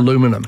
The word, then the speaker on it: aluminum: Right, but the Americans don't put the I, so it's no. aluminum. – Aluminum, and spell it differently, and it is the original pronunciation.